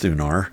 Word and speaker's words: Thunar: I think it's pronounced Thunar. That's the way I pronounce it.